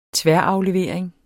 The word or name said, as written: tværaflevering